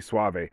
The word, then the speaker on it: Soave: Robbie Suave. He's got a hell of a cool last name. It's Soave, but he pronounces it Suave, like Rico Suave.